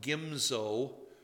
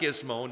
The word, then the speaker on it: Gimzo: They captured Beth Shemeth, Aijalon, Gederoth, Soko and its villages, Timnah with its villages, and it's pronounced Gimzo. Let's all say the other one. Gizmo. It's not Gizmo, nor is it Gonzo, who had a long nose, with his villages, and they settled there.